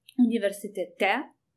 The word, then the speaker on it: universitete: And if you say you are at university, you change the "us" ending to "e," universitete. You also change the stress position to the last syllable.